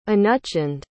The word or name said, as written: anuchand